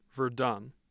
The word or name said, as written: Virden